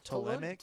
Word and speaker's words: Ptolemaic: Pronounce this for me. It's spelled Ptolemaic. – Pt what now? – Olemaic. Ptolemaic. – Ptolemaic. Ptolemaic. That sounds good. – Okay. – Whatever. – Definitely has the T sound at the beginning.